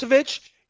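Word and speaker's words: Vučević: Like, I get his pronunciation wrong. The guy in Orlando, Vukovic, you know, yes. – Vucevic.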